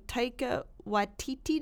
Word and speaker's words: Taika Waititi: Yeah, Hunt for the Wilder People, Taika Waititi, did I say it right? – You nailed it.